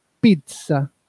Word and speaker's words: pizza: Así que a raíz de este tuit aclaró que al ser un extranjerismo no adaptado, la forma correcta de pronunciar pizza es pizza, con la T antes de la SA.